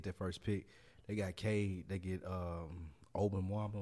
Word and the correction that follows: Wembanyama: or whatever his name is. I can't never pronounce it. – Wembanyama. – Wembanyama.